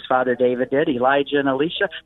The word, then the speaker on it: Elisha: That's how I pronounce it in the movie, by the way, Elisha. – Elisha. – To make the distinction between Elijah and Elisha. – I see. – Because otherwise they sound too close together.